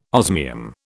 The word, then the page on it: osmium: British English